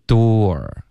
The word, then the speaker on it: door: door 的这个发音呢是一个倒 C 的这个元音后面带一个 R， OK，然后它的发音就是 door，开头呢其实我们的嘴唇是撅起来的，是嘟起来的，有一点嘟的这个感觉，嘟，然后嘴唇要分开，有一个窝的音 door， door，后面呢再加一个二 door。